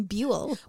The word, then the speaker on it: Buell: What do you think of that middle name, Buell? – Buell. – B-U-E-L. – Buell. – Rhymes with fuel.